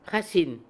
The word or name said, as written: Hassein